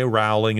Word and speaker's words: Rowling: Rowling.